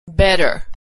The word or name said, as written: better